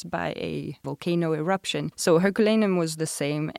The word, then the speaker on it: Herculaneum: So there's this ancient city, Roman city of, I'm not sure how you pronounce it in English, Herculaneum? – Yeah, Herculaneum, yeah.